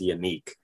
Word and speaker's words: Yannick: And then he was like, yeah, my name is pronounced Yannick.